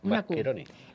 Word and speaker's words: Mascheroni: No lo conozco, pero sé que los italianos la C y la H la pronuncian como si fuese una Q. – Mascheroni.